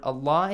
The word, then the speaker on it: Allah: If the previous sound or the previous harakat on the previous letter is a fatha or a dhamma, the word, the lam of the word Allah is pronounced heavily. You remember that? – Yes. – Narallah, this heavily. Narallah, law, law, not lah.